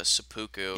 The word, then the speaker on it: seppuku: Oh, it's seppuku.